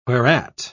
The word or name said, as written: whereat